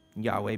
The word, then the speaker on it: Yahweh: Yahweh being the, our best guess at the Hebrew pronunciation of God's proper name, the Lord, usually Lord in all caps in your Bibles.